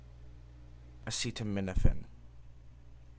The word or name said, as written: acetaminophen